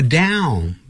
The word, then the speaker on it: down: It is amazing what you can accomplish， accomplish，好，要这么念， just by writing it down，念成那个 down，不要念成 down， down，先念大小的大， down，不是到到到达的到， down， down，那就不对了，到跟大嘴巴是张开的量是开度是不一样的， down， down。But that is just the first step，但那还只是第一步。